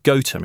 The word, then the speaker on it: Gotham: Or, as it's pronounced locally, Gotham. It's spelled G-O-T-H-A-M.